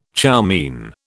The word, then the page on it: chow mein: British English